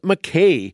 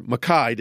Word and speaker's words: Mackay: Of the councillors, James Mackay, or Mackay, depending on your pronunciation, was appointed the president and speaker of the council.